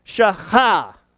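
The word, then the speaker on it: Shaha (שחה): It's Shin and Het and He. I know these letters look a lot alike, but there's a little gap in that letter, and there are different letters. And it's pronounced Shaha.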